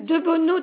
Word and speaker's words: De Bono: Alors, De Bono Dominique. – De Bono, ça s'écrit D-E ? – D-E-B-O-N-O.